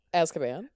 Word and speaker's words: Azkaban: Azkaban. – Gap Creek. – Weird that you don't know how to pronounce that. I got to say, you never heard the word Azkaban being pronounced.